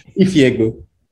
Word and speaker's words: Ifeagbu: Ifeagbu. – Ifeagbu. – Yeah, you know? It's literally pronounced how it's spelled. Like, you got it right.